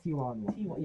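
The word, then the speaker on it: Taijuan: Tijon Walker? Is that Tijon? – Tijon.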